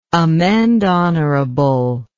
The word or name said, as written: amende honorable